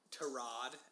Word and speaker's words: Tyrod: Well, maybe it was because he was under, like, Tarod, as we learned, that's how you pronounce his name.